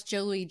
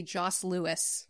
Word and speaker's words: Joe Louis: Now, I do know people that used to pronounce Joe Louis Joss Lewis. And that's clearly completely incorrect.